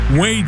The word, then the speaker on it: Whey: We're going to play Way or No Way, and it's spelled W-H-E-Y because it's all about Way Jennings, the grandson of Waylon Jennings, who's going to be in concert at the Depot Village in Gainesville on October 22nd.